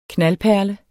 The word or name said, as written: knaldperle